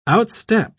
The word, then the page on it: outstep: British English